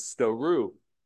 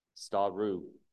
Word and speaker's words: stauroo: Now, there was a closely connected word, the verb form of this noun for cross or stouro or stouros, which was stouru. That is translated into the English as crucify. That's spelled S-T-A-U-R-O-O, stouru.